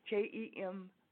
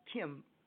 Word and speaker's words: Kemp: Emme, Emme Kemp. Kemp. It's not Kim, and they look for that too. Kemp. Emme Kemp. All, A-L-L, music, M-U-S-I-C, .com.